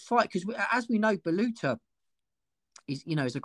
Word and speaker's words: Baluta: Yeah, Baluta, yeah.